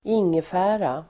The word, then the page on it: ingefära: [²'ing:efä:ra]